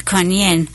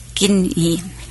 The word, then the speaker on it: Guan Yin: Yes, when we were in Thailand, we actually saw a Chinese Buddhist temple in Thailand that was for the bodhisattva Kuan Yin or Gin Yi. And several different mm-hmm. pronunciations depending on the country. – Kuan Im, Guan Yin,